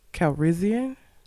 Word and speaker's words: Calrissian: Calrissian.